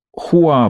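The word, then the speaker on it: Хуа (花): «Хуа» произносится оно в Поднебесной империи, и я вижу эфемерные белые кружева хризантемы или пиона.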